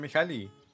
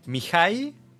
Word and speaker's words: Mihaly: De hecho, inclusive el nombre lo están diciendo mal, porque no es Mihaly. Es Mihai, Mihai, así, Mihai, Mihai.